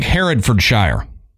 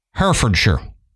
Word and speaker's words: Herefordshire: See, to me, being a Southern American, it looks like Herefordshire, and that's how I would pronounce it. But I know if I say Shire, I get people, our UK listeners say it's not Shire, it's Sure. So It's probably Herefordshire.